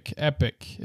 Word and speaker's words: epoch: Epic is the way you pronounce that. – Epic? – I'm sorry. – Is it really? – Just like essentially the same thing as epic.